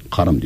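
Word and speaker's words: kâr: Onu da aynı şekilde o şekilde inceterek telaffuz etmesi gerekir. Kar diye yazıyor orada. Saadet-i Ebediyede. A'nın üzerinde inceltme işareti var.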